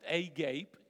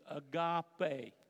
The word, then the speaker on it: agape: It's not pronounced agape, it's agape.